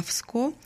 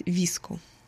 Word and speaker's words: VSCO: Для тех, кто знает и, возможно, пользуется приложением на телефоне для обработки фотографий, которое пишется VSCO, и по-разному люди ее называют, вроде как я называла всегда VSCO, но вот на английском языке ее правильно называть VSCO.